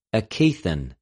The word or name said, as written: ἐκειθεν